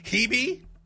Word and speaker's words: Hebe: The three options: Hebe, which rhymes with Stevie.